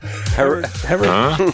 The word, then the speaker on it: Herradura: Herradura. – That's exactly how it's pronounced.